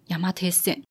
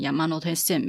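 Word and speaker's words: 山手線: 但是明明这个字里面就没有 no 啊，不是应该要念成 Yamate Sen 的这个没用小知识。其实最一开始，山手线在一八八五年开业，然后他送资料的时候，他是写 Yamanote，所以汉字山跟手中间有一个片假名的 no，所以最一开始最一开始是念成 Yamanote Sen 没错，只是中间曾经有一段时期，就是大家都会叫它 Yamate Sen，然后甚至呢也有写过 Yamate Sen 过，那曾经这个 Yamate Sen 是一个曾经存在的一个年代这样子。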